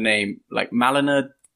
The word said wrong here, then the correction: Milena: DeMartini. – Oh yes, Malina. – Milena. Milena. Yeah.